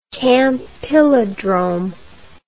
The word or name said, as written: campylodrome